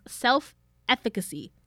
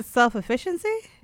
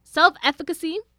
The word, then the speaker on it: self-efficacy: So the first tier is self-efficacy. Said it right. I know I did. – You sure it's not self-efficiency? – Nope, because that's not how it's spelled. It's efficacy. – Efficacy. Oh, wow.